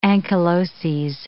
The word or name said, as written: ankyloses